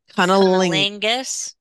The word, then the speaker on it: cunnilingus: Cunnilingus.